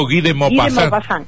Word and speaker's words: Guy de Maupassant: Eh, vamos a hablar de Guy de, ¿Está bien pronunciado? Guy de Maupassant.